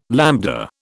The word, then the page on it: lambda: British English